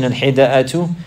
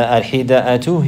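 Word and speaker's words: الحدأة: The al-hida'atu, he's mentioning here how to pronounce it. بكسر الحاء. So you say حي وفتح الدال. You say داء مهموس. يعني في همزة. It has a همزة in it.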